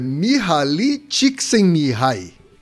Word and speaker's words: Mihaly Csikszentmihalyi: Fico elogiado é Mihaly Csikszentmihalyi.